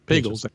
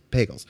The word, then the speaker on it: Pagels: Is it Pagels or Pagels? – Pagels. – Pagels.